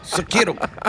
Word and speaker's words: Sekiro: Sekiro, maybe? Sekiro? How do you say this? Shadows die twice. – Sekiro.